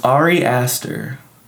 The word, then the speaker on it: Ari Aster: Ari Aster. Well, that's how you say his name.